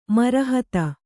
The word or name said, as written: ಮರಹತ